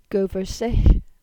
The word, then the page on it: Goversygh: Cornish